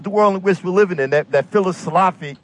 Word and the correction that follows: Phyllis Schlafly: Oh, Phil, you mean, no, you have to say it a certain way. National Treasure Phyllis Schlafly.